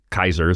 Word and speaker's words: Caesar: His name was actually Kaiser, is how they pronounced it back in the day.